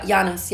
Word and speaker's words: Giannis: I asked my Greek friend, how do I say it? It's with a Y. Giannis.